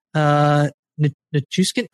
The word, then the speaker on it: Nichushkin: Nichushkin, I'm so sorry. I feel so bad. I have to. – You said it right.